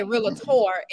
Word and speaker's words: realtor: you're putting emphasis on the A. You don't need to. It's just realtor.